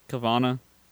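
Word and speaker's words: Caverna: Yeah, it's cave and Nirvana, like Carvana.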